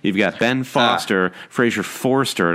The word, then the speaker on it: Forster: Thinking, I still feel like you're adding a syllable in Fraser Forster's name, though. It's just Forster, Forster.